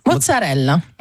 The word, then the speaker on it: mozzarella: Déjà, est-ce qu'on prononce bien ? C'est mozzarella ? – Mozzarella. – Mozzarella. Mozzarella. – Vous le dites très très bien.